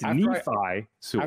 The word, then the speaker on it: Nephi Sewell: and I guess we've all been saying his name. Every single one of us, every single reporter was saying his name wrong. So anyway, it's Nephi Sewell.